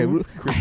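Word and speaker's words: Ruth's Chris: Yeah, Ruth Chris. I hate trying to say that name because it's the S on the end of the Ruth or whatever.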